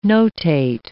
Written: notate

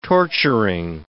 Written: torturing

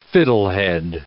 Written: fiddlehead

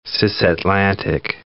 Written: cisatlantic

